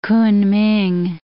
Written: Kunming

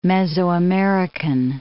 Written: Mesoamerican

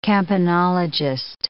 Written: campanologist

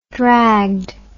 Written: dragged

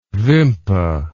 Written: vimpa

